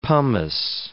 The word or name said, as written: pomace